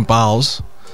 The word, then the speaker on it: Biles: I have to give a shout out to gymnast Simone Biles. Hope I'm saying her name right. It's not Billis, right? – It's Biles. No, it's Biles.